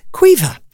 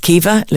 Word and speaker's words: Caoimhe: At number three, the most popular girl's name in Ireland is Caoimhe. I know some people will pronounce it as Caoimhe. Listen, I'm not going to fight with you over this.